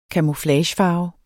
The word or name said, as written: camouflagefarve